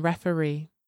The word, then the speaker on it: referee: The stress falls on the last syllable in kangaroo and referee.